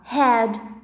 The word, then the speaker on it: head: No inglês o H tem som de R. Então a gente fala head, hair, here, um, Uh, uh, uh.